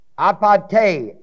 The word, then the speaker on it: apate: Then there's another Greek word, apate, A-P-A-T-E, long E, which is deceit when the bait is taken.